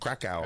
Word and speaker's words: Krakow: Krakow.